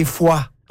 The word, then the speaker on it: Foix: Carcassonne et Foix.